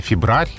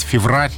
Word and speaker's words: февраль: То есть он был бы, наверное, «февраль» или «феврарь», но произносить «феврарь» не так удобно, как «февраль».